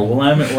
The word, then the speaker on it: Willamette: And remember, Willamette rhymes with damn it, so.